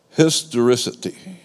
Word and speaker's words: historicity: It's called historicity. Historicity. You can remember it because it rhymes with authenticity.